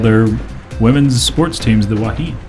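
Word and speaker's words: Wahine: Waheen of Hawaii. Brennan educated me earlier. This is how you say, that's what they call their women's teams there, the Waheen.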